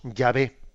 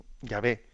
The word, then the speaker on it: Yahvé: pero dichas en nuestro alfabeto son la Y, H, W, y nosotros las pronunciamos Yahvé. Mm, Yahvé. Eh, del, siglo XVI al XIX, en muchas Biblias, se transcribió por Jehová.